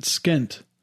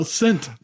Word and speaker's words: scent: Like, so if neither one of them was silent, we could pronounce it skint. – Skint. – Like, what skint is this lip balm? – Look at all those candles, all those different skints. It's my sixth skint. – No, scent.